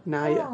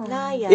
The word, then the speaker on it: Nahia: And her name rhymes with Saya. Naya. – Oh. Naya. – Interesting spelling, though. N-A-H-I-A.